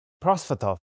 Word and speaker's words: Prosvetov: Prosvetov.